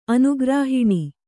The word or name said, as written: ಅನುಗ್ರಾಹಿಣಿ